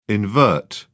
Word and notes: invert: British English

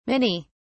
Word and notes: minnie: British English

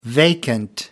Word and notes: vacant: American English

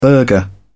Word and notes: burgher: British English

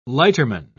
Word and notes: lighterman: British English

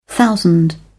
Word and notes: thousand: British English

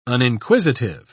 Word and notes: uninquisitive: British English

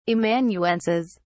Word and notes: amanuenses: British English

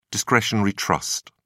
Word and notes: discretionary trust: British English

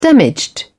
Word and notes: damaged: British English

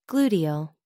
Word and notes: gluteal: American English

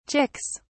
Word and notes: chicks: British English

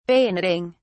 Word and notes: bayoneting: British English

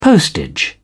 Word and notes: postage: British English

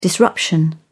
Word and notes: disruption: British English

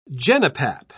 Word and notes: genipap: British English

